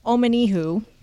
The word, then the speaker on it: Omenihu: All right, so Chiefs defensive end, Charles Omenihu, I believe Omenihu.